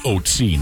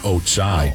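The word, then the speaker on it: Ötzi: He says the mummy is, the pronunciation of its name is Otsi, not Otsai.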